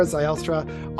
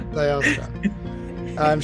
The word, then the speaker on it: Zylstra: On today's episode, I'm pumped to have Sarah Zylstra on the podcast with us. I said it wrong. I totally said it wrong. Sarah. – No, you were close. – I was close. Zylstra.